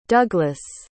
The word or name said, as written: duglas